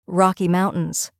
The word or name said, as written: Rocky Mountains